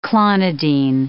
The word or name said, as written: clonidine